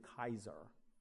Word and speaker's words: Caesar: you were the Kaiser. That's how you pronounce the Latin Caesar. The C is a hard K sound, Kaiser.